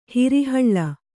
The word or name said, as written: ಹಿರಿಹಳ್ಳ